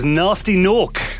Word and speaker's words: Gnasty Gnork: But no, it was Nasty Nork. Gnasty Gnork, actually, spelled with G's.